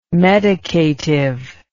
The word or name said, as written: medicative